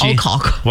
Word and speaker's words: Alcock: Be nice. That's Alcock. – Well, you got to say it like that, right? Mike Babcock.